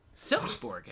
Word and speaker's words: Silkeborg: Silkeborg, everybody in the Europa.